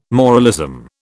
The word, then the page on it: moralism: British English